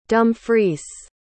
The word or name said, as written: Dumfries